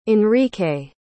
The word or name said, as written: enrique